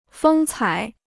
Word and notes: 风采: fēng cǎi

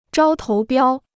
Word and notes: 招投标: zhāo tóu biāo

